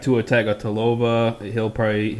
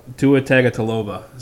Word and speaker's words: Tua Tagovailoa: I got Tua Tagovailoa. He's going to claim that. – You actually said his name right.